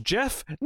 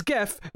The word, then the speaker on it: GIF: Look, he's wrong, and while we're on it, the creator of the GIF tried to say it's pronounced Jeff. No, it is GIF. You are wrong.